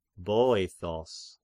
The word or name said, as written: Boethos